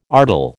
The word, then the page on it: artel: American English